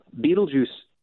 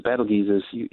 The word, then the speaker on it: Betelgeuse: Betelgeuse, or it was Betelgeuse, is sometimes pronounced, is a variable star.